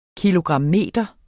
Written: kilogrammeter

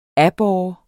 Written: aborre

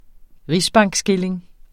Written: rigsbankskilling

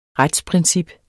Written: retsprincip

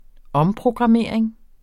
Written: omprogrammering

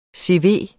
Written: CV

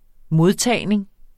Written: modtagning

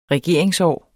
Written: regeringsår

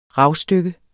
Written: ravstykke

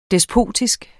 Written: despotisk

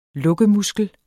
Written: lukkemuskel

